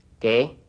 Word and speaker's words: qué: The Q in Spanish is always followed by a U when written, but this U is never pronounced. Repeat. – ¿Qué?